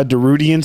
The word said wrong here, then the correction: Darude: It's Darude.